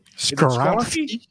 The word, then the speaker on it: Skoronski: Skoronski? – Skoronski. Oh, I messed it up. The pick is in. Act like I didn't say that before. We're going to go with the Northwestern tackle, Peter Skoronski.